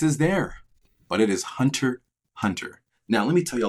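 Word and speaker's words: Hunter × Hunter: Yes, there is an X, but it's pronounced Hunter Hunter.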